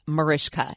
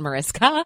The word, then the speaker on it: Mariska: And guys, it's pronounced Mariska, not Mariska.